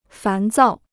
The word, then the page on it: 烦躁: fán zào